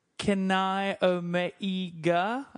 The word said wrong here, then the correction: Kenny Omega: Is that how you say it? – I'm pretty sure it's Kenny Omega.